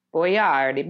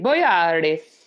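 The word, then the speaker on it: Boiardi: That is not how you say his name. His name is pronounced Boyardi. Boyardi.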